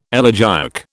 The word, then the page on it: elegiac: British English